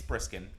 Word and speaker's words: Briskin: Listen, my name's Briskin. Kind of rhymes with Friskin.